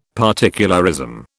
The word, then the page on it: particularism: British English